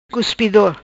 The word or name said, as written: Cuspido